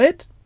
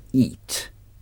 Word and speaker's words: eat: To eat. Eat. To eat. Ça s'écrit E-A-T. Mais ça se prononce eat. Eat. – Eat. – Eat. Il ne faut pas confondre eat avec it.